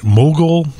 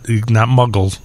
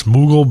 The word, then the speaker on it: Mughal: This is I was looking for something a little different, and so I went to I have to pronounce it right, Mogul Bakery, not Muggles. Like, it's not Harry Potter. It's Mughal Bakery, Mughal, mm-hmm.